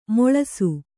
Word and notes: ಮುಳಿಸು: muḷisu

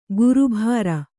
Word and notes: ಗುರುಭಾರ: guru bhāra

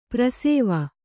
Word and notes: ಪಾರ್ಶ್ವ: kannada

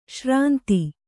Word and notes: ಶ್ರಾಂತ: śrānta

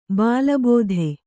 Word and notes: ಬಲಭೇದಿ: bala bhēdi

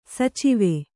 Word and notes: ಸಚಿವೆ: Kannada

